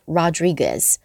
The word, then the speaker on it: Rodriguez: To former Yankee star 前洋基队的一位明星 Alex Rodriguez， Rodriguez 这是一个拉丁的姓氏， Rodriguez 那个 U 是不发音的， Rodriguez。